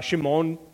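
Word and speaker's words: Shimon (שמעון): Of course, it wouldn't have been pronounced like that, but you would have called him Simon. Shimon is actually how it would have been pronounced.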